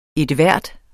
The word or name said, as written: enhver